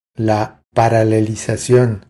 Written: La paralelizacion

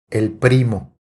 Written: El primo

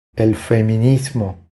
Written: El feminismo

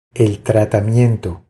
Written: El tratamiento

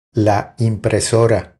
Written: La impresora

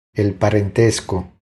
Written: El parentesco

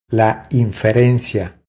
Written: La inferencia

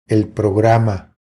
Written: El programa

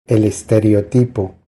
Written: El estereotipo

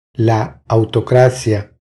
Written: La autocracia

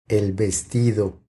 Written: El vestido

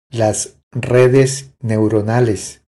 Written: Las redes neuronales

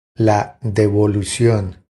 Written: La devolucion

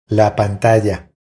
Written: La pantalla

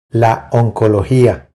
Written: La oncologia

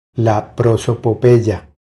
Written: La prosopopeya

